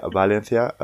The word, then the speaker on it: Valencia: Ich habe Akzent, war ja auch mal im Homestay-Programm in Valencia, man sagt ja Valencia, also das V spricht man als leichtes B aus.